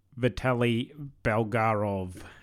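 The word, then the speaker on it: Vitaly Bulgarov: Vitali Blorogov. – I'm going to butcher it, but I think it's Vitali Vitali Bulgarov.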